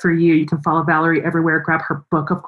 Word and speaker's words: Valorie: Just remember Valorie spelled like Valor, V A L O R I E.